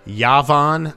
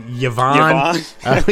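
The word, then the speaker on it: Yavin: Yo, how do you say that planet's name? – Yavon. I mean, Yavon. We said it last time. Yavon 4. Yavon. We've already made this joke on Dork Wars the podcast, so we're kind of rehashing.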